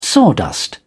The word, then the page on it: sawdust: British English